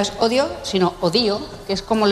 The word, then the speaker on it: Hodio: La herramienta se llama Odio, pero no se escribe normal. – Vamos a poner en marcha la herramienta que hemos llamado Odio. H-O-D-I-O. – Odio.